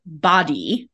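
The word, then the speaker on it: BODi: They're rebranding as, I'm pretty sure this is supposed to be pronounced body, but it is spelled capital B, capital O, capital D, lowercase I.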